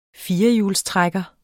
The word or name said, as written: firehjulstrækker